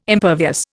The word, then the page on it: impervious: Indian